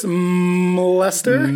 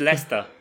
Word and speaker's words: Leicester: I believe it's Mlester. – No. – Mlester. – No. No, you can't pronounce the M. – The M is silent. Gotcha. Okay. – So it's Leicester.